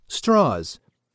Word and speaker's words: Strauss: Proud of both his Jewish and his Southern heritage, Strauss, spelled Strauss, pointedly insisted on pronouncing his last name as Strauss.